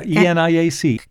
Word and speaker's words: ENIAC: The first ENIAC computer, is that how you say it? – Yeah, ENIAC.